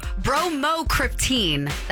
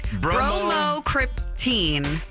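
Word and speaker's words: bromocriptine: Brocryptonine? Bromocryptine. That's it. Bromocryptine.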